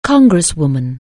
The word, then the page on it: congresswoman: anglais